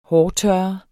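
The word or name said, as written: hårtørrer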